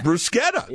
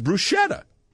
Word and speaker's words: bruschetta: Because everybody says bruschetta. Because the CH, you know, could be a sh or a ch. But in Italian, CH is ca.